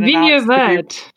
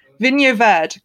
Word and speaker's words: Vinho Verde: Vinho Verde. – Vinho Verde, great.